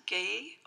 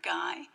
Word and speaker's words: gey: And the word, it's pronounced gay or guy. It's spelt G-E-Y.